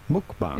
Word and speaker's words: mukbang: How do you go about pronouncing it? Mukbang. – Yeah, it's mukbang.